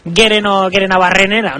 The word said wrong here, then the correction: Gerenabarrena: ¿no? – Bueno, casi. Ahí está, fatal. – Gerenabarrena, sí.